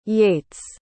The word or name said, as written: Yates